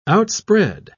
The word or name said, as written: outspread